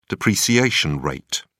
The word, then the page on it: depreciation rate: British English